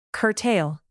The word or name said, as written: curtail